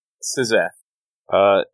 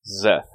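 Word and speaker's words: Szeth: The S is mostly silent. It adds mostly a little bit of emphasis, silent. But that's it. – Zeth. It's like when a car goes by, it's like... Zoom! It's like that. – Yeah, it's definitely not its own syllable.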